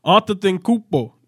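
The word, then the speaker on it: Antetokounmpo: On Tupo or Kawhi? – That's a horrible. You butchered his last name. Arthur Tinkupo is his last name.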